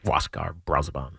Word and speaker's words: Wascar Brazoban: Good pronunciation. – Thank you very much. – Wascar Brazoban. – That's right.